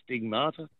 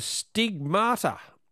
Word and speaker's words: stigmata: I was begging that I wasn't the next one, but now, and then I was praying I was, so hopefully I've pronounced it right, stigmata. – Correct. Yes. They are the stigmata.